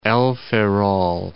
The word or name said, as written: El Ferrol